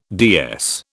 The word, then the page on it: DS: British English